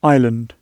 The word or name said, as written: island